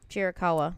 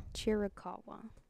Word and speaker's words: Chiricahua: How do you say it? Chiricahua? – Chiricahua. – Chiricahua. – Yes, that is the other place.